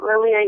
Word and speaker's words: Lilia: They can Facebook me at Lilia Yep. It's just my name. It's spelled L-I-L-I-A, last name Y-E-P.